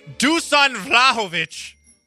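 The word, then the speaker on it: Dušan Vlahović: Dusan Vlahovic.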